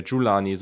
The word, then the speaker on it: Jelani: Jelani Woods, ähm, äh, oder nee, jetzt habe ich ihn wieder falsch ausgesprochen hier. Julani, so heißt der.